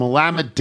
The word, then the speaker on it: Willamette: I glanced at the label and I exclaimed, wow, it's from Willamette Dammit Valley. This drew a confused expression to which I replied, it's from Tony's show, so people pronounce it correctly.